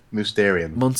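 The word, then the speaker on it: Mousterian: Obviously Monsterian tools. I think that's Mysterian. Really cool. What? – Mysterian. Yeah.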